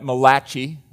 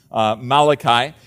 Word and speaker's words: Malachi: Some people call it Malachi, but that's not the pronunciation. Malachi.